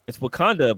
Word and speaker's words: Wauconda: It's Wakanda, but it's spelled Wauconda, and it's in Illinois. So it's there's literally a city called Wakanda, Illinois, but it's not Wakanda.